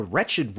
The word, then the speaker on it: wretched: But anyway, after sending the letter, the witch went to visit her cousin, the Wretched Witch of the Wastelands, which I guess technically is, what's the word? – For alliteration? – Alliteration. Although the W in wretched is silent, so.